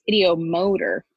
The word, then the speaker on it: ideomotor: And in 1853, Michael Faraday, he began a series of experiments that proved to him, at least, that, oh, I'm sorry, it's not ideometer, it's ideomotor effect. – Ideomotor, okay. – Motor. I knew I was saying that wrong.